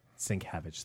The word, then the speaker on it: Sienkiewicz: Sienkiewicz, thank you.